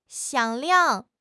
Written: 响亮

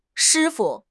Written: shi1 fu5

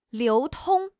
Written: 流通

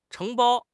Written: 承包

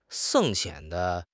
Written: 解剖

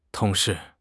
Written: tong2 shi4

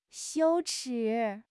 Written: 羞耻

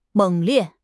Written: 猛烈

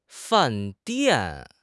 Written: fan4 dian4

